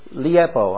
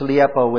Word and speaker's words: leipo: This Greek word to leave is liepo. I'm going to say it that way, and it's spelled L-E-I-P-O, which means to leave. L-E-I-P-O.